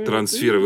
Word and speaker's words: трансферы: Трансферы. Вы не представляете, как сейчас вот моя руководитель Александра Борисовна Крамаренко, как она просто, у нее мед по душе, когда вы сказали слово трансферы именно с этим ударением.